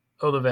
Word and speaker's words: Olave: Olave, I don't know how to say his name.